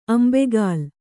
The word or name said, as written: ಅಂಬೇಗಾಲು